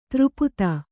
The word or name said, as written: ಧಾರಾಪಾತ